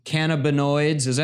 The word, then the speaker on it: cannabinoids: Another one, upcoming trials of the impact of cannabinoids. Am I saying that right? – Yeah, you said it right.